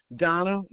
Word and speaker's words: Donna: Donna Winder, W-I-N-D-E-R, Winder, spelled like Winder. – Donna, D-O-N-E-A-N? – D-O-N-N-A.